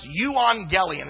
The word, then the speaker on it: euangelion: But when a Greek person heard the word, and to transliterate this, this would be pronounced euangelion, and it's pronounced good news for us.